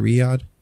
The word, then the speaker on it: Riyadh: Riyadh." Is that how you say it? Riyadh? – Yeah.